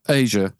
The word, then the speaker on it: Aja: So the Dan for me is, I don't even know if I'm going to say it right. Aja, am I saying it wrong? – Yeah, Aja. I think it's Aja. Aja. Sorry.